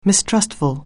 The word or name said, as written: mistrustful